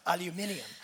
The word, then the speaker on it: aluminium: Their apparent inability to pronounce the word aluminium correctly. It's aluminium.